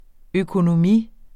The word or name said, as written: økonomi